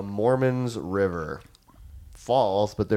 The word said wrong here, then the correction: Moormans: No, it's spelled Moormans.